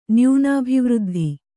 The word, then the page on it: ನ್ಯೂನಾಭಿವೃದ್ಧಿ: nyūnābhivřddhi